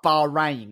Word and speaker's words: Bahrain: We'll have a recreation of Spain where Rosberg and Hamilton took each other out, but Bahrain. It rhymes, you see. It's like Spain without the S and P, but with bar on the front of it spelling wow.